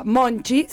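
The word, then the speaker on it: Monte: Obviamente es un tema de Titas con Marisa Monchi, sería la pronunciación correcta.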